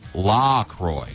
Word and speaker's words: LaCroix: Officially, it's pronounced LaCroix, so it rhymes with... What? Oh, LaCroix. Well, I already knew that. It says it rhymes with enjoy, so, okay, yeah, we know.